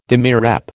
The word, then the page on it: demirep: American English